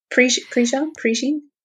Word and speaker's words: Prejean: It's a book called River of Fire by Sister Helen Prejean. Is that how you pronounce her? Yeah. – Prejean? – I think it's.